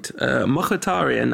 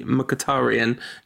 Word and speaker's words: Mkhitaryan: Well, not so much practicing as listening to a pronunciation guide. Mkhitaryan, apparently. Mkhitaryan, in fact. Sorry. I was concentrating so hard on the bit that I got the second part wrong. But yeah, I think the Rantcast official pronunciation might be Mkhitaryan, just to avoid patronising anyone or getting anything wrong.